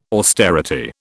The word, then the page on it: austerity: British English